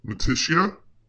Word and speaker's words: notitia: But Reformed theology analyzes faith as notitia.